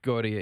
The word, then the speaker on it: Gauthier: Julien Gauthier, right? – Gauthier, yeah.